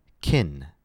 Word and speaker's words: can: So can will sound like kin.